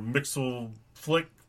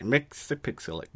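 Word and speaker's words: Mxyzptlk: So I picked up Action Comics 977, and this, it starts the new Superman after his Mr. Mixel Flick. – Pixelic. Mix-a-pixelic.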